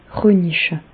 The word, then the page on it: röniche: Bas-Rhin; Reichshoffen